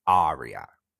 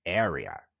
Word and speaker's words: area: What they're trying to say is area, but they say aria. So the A pronunciation is actually A, the letter, the sound A. So you say area, the area around my house, or the city center, the area within the city center, for example, or the manufactura area, not aria, it's area.